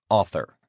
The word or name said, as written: author